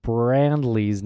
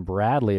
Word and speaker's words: Brandley: I pronounced Clarence Brandley's name wrong. I think I called him Bradley a few minutes ago.